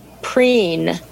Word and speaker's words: Preen: And the other thing I found very, very useful is a product called Preen.